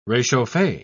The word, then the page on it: rechauffe: British English